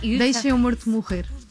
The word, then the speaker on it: Deixa Meu Morto Morrer: It's in Portuguese, it's very hard to pronounce Portuguese, but it's almost perfect. – No, you Deixa Meu Morto Morrer.